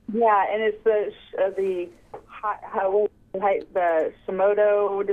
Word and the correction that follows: Hashimoto's: disease is with it. I can't pronounce it correctly. – Hashimoto's? – Yes, mm-hmm.